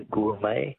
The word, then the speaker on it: gourmet: Words that end with a silent T. Beret is B-E-R-E-T. Which word for a connoisseur comes from an old French word meaning wine taster? – Be a gourmet. – Gourmet, yes. Correct. G-O-U-R-M-E-T. All right.